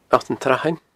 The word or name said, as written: Allt an t-Srathain